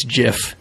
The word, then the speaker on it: GIF: No, the creator said it was GIF. It's a soft G.